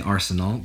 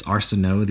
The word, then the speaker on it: Arsenault: Um, my name is Ian Arsenault. Um, a lot of people where I'm from like to say Arsenal because it is actually French, um, but it's Arsenault. The LT is silent.